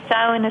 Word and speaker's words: Samhain: And it's Samhain, is what you call it. Ihaona is Halloween. Samhain is how you pronounce it.